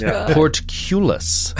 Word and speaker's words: portcullis: He was like, you're pronouncing it incorrectly. It is portcullis.